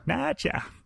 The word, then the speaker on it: Nacha: He's referred to in different ways, but I think we're going to stick with Nacha just because we're stupid Westerners and we can pronounce that more easily. – Nacha. – Nacha. – Like nachos.